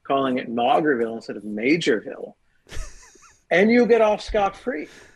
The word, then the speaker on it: Maugerville: Well, I'll tell you, based on my emails, I certainly said Maugerville. It's spelled like Maugerville. Mm-hmm. I assumed it was Maugerville. The actual place name is Majorville. As many listeners connected to New Brunswick were kind enough to inform me of my error. – That was a major mistake you made. – Well, the ridiculous town spells it Mauger. Who would pronounce that major? I just assume Mauger, but anyway, it's Majorville.